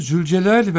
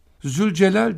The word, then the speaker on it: Zülcelal: Şimdi bakın, Zülcelal dedik ki zü kelimesi, oradaki pertek zeldir, zü.